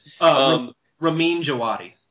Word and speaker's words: Ramin Djawadi: Ramin Djawadi.